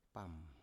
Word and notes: pam: Biak

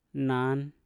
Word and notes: nan: Mpur

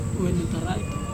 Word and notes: wenutaraito: Serewen

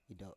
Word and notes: ido7: Biak

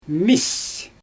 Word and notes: miis: Xyzyl

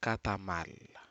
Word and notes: katamari: Aneityum

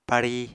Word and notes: pari: Apatani

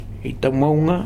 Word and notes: Itamouga: Futuna

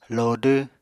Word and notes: lodw: Apatani

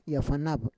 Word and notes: yafanav: Roon

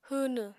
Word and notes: hwwnw: apatani

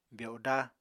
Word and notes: byodá: Apatani

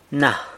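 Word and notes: na: San Lucas Quiaviní Zapotec

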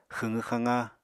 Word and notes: xwqw-xaqa: Apatani

